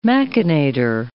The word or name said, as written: machinator